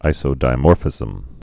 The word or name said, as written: isodimorphisms